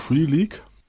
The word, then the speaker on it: Free League: oh Gott, wie spricht man diesen Namen wohl aus? – Free League. – Andi, was sagst du? – Ja.